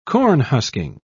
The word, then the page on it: cornhusking: British English